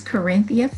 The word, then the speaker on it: Karynthia: Okay, my name is Karynthia Phillips.